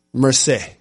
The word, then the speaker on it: Marseille: Marseille.